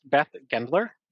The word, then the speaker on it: Beth Gendler: Today's guest, Beth Gendler.